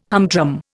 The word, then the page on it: humdrum: Indian